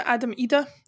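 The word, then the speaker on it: Idah: In terms of performances, apart from his two goals, I thought Adam Ida, and that is how you pronounce it, Adam Ida,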